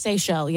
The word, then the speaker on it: Seychelles: Is it pronounced Seychelles? – Seychelles, yeah. – The Seychelles, yeah. The island, islands. – S-E-Y-C-H-E-L-L-E.